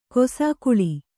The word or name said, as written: ಕಿಸುಗುಳಿ